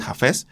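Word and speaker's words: Hafiz: Entonces, quien tenga por ahí curiosidad, búsquese poemas de Jafés, que se escribe H-A-F-I-Z, y están, bueno, este se me hace muy bueno, entonces pónganse por ahí a investigarlo un poquito.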